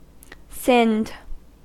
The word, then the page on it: sinned: American English